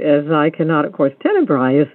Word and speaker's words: Tenebrae: Tenebrae.